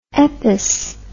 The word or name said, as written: eppes